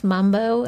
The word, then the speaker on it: Manbo: It was spelled M-A-N-B-O, but how is that actually pronounced? – It's pronounced Mambo, and most people spell it with an M, but the actual official Creole spelling is M-A-N-B-O, Mambo.